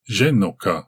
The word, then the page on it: zehnoka: Galindri